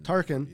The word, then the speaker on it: Tarkin: You know, Peter Cushing played Grand Moff Tarkin. Is that how you pronounce it? – Grand Moff Tarkin. – Tarkin.